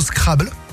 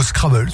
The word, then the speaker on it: Scrabble: Alors, le deuxième, ça peut rapporter gros au Scrabble, ou au Scrabble, suivant votre prononciation, puisque le mot whiskies, le mot whisky, donc, dans la langue de Shakespeare, W-H-I-S-K-E-Y-S,